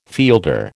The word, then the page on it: fielder: American English